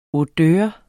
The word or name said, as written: odør